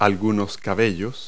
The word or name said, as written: hair